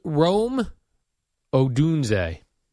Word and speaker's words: Odunze: Odunze. Is that how you pronounce his name? – Rome Odunze.